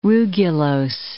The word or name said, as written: rugulose